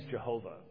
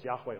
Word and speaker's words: Yahweh: Let's go ahead and use Jehovah. That's not a possible pronunciation. Jehovah has three syllables. Yahweh only has two syllables.